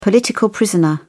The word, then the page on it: political prisoner: British English